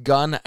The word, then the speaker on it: Guin: Uh, I believe it's pronounced Gun Alabama G U I N, and those were the those were your F5s.